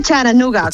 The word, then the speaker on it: Chattanooga: Se dice Charanooga, con la T como muy suave, sí.